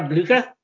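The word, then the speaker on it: Blücher: I, I would say, then, uh, I would say, I obviously, I, I, I, well, so I would say Frau Boat, Frau, how do you pronounce her name? Frau uh, Boucher. – Oh, Frau Blucher.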